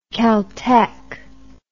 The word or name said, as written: caltech